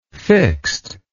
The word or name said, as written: fixt